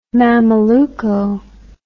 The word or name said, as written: mameluco